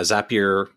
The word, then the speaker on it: Zapier: but is it Zapier? – Yeah, Zapier.